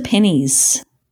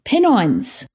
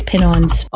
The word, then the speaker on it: Pennines: And cross the Pennies. What's the penny? Pennines. Oh, I said that wrong too. – I don't know the pronunciation there, so. – Oh, I think it's Pennines.